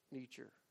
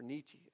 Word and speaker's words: Nietzsche: But his name was Friedrich Nietzsche, or Nietzsche, as some people pronounce it.